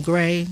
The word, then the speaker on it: Gray: S-A-U-D-A-M-A-N-I. Last name Gray, G-R-A-Y.